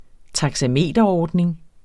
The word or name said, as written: taxameterordning